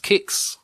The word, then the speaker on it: Qix: So, search for Q-I-X. – Oh, I remember that. Apparently, it's pronounced Kicks. – Yes. – You just saw that on Wikipedia as well. You've been pronouncing it wrong for all these long 37 years.